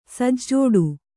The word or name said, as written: ಸಜ್ಜೋಡು